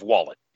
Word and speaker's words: Wollett: It's pronounced like the thing you put in your back pocket and store. So it's Steve Wollett, yeah.